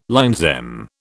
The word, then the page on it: LL M: British English